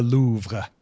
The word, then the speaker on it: Louvre: How do you pronounce that? Because I've always heard of it as the the Louvre. – Louvre. It's, it's the Louvre.